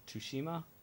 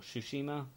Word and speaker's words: Tsushima: 5 was Ghost of Tsushima, or Tsushima. Sorry, I pronounced that wrong last time.